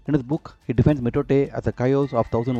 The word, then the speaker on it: mitote: The word is pronounced mitote.